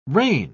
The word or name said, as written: rein